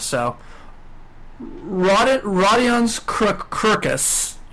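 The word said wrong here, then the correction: Kurucs: Rodion Kurix. – Kurix. I was very off.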